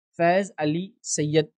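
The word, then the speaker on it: Faiz Ali Syed: देखिए मेरा नाम है फैज अली सैयद,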